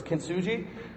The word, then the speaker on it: kintsugi: It's kintsugi, I think is how you say it. I've heard people pronounce it multiple different ways, but it's kintsugi.